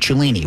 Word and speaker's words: Cellini: And when you do that, use the promo code Cellini, which is my last name, which is spelled C-E-L-L-I-N-I.